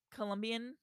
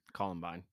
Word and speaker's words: Columbine: Colombian Columbine.